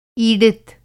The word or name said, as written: ईडित